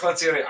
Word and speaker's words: Lencioni: This is episode 13, and I have not had uh, Patrick Lencioni. Did I pronounce that correctly? – Yeah, you did. – Patrick Lencioni.